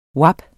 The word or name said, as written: WAP